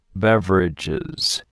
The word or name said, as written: beverages